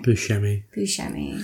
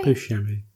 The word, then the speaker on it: Buscemi: Buscemi. – Are you sure that's right? – Buscemi.